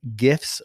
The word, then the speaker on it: GIF: So before I talk about my tip from Eric Kurtz, I will start off by saying right up front, I'm a hard G guy. You'll understand this. You can give me all the excuses, but when I talk about this next one, creating your own GIFs with Google Slides, I am a hard G GIF guy.